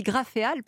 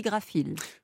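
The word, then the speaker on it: Graphéal: Alors, j'ai dit Graféal parce que ça s'écrit G-R-A-P-H-E-A-L, mais on dit Graphil.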